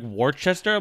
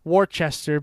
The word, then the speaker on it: Worcester: I remember when you came out and visited and you looked at that and you're like, Worcester? I'm like, no, it's pronounced Worcester.